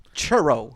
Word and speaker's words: churro: Of course it is, my sweet curro of a man. – It's pronounced churro, like burro with a cha instead of a buh.